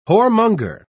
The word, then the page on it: whoremonger: British English